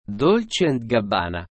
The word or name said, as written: Dolce Gabbana